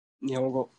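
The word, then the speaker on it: Nihongo (日本語): Nihongo? – Nihongo.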